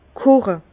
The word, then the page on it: koche: Bas-Rhin; Herrlisheim